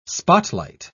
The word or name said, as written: spotlight